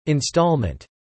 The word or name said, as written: installment